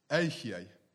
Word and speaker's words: Ehyeh: Now, in Hebrew, I am is Ehyeh.